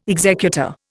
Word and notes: executor: Indian

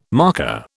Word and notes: marker: British English

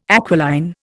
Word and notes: aquiline: Indian

